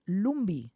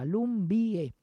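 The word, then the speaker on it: Lumbye: como llamaron a Hans Christian Lumbi. Se escribe Lumbie, con Y, Lumbie.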